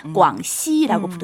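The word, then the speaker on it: 광시 (广西): 중국어 발음하면 광시라고 부르죠.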